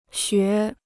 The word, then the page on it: 学: xué